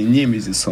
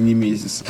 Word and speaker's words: Немезис: Вот, кстати, про ударение. Немезис он, а не Немезис.